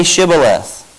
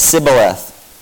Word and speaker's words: Shibboleth: When he said no, they said, then say Shibboleth. And he said, Sibboleth, for he could not pronounce it right.